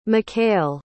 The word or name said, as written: mckale